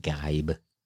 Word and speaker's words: Geib: I said Geib. Geib. It's pronounced Geib.